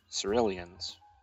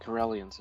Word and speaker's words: Corellian: Well, it depends on if you make that a soft C or a hard C. – I think Corellians is probably right. – It's a K. Never mind. – It's a hard K or something.